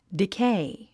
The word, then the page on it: decay: dikéi; ディケェイ